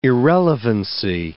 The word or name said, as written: irrelevancy